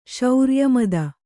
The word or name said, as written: ಸಾರಾಯಮತಿ